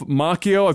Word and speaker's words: Macchio: Ralph Macchio, I think it's a hard C's, double C there, I think.